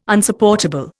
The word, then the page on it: Unsupportable: Indian